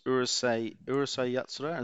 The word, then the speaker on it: Urusei Yatsura: Now, you said the next one was Urusei Yatsura.